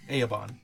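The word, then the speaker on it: Aaban: There is also an Aeabon. – Cinnabon. – Aeabon. – Yeah. I almost did it again, an Aeabon. It's an Aeabon. It's A-A-B-A-N. – Okay. – Messed up A-A-Bon. – A-A-Bon is correct pronunciation.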